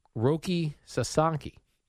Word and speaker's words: Roki Sasaki: During one of these games, Jerry, Japanese pitcher Roki Sasaki.